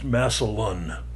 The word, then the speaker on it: Massillon: One point of correction, the name of the Ohio town that the movie is set to be hosted by, Massillon, is pronounced Massillon. I don't know how I pronounced it. – I think you said Massillon. – Massillon. – Massillon is how you say it. – Like masculine without the C.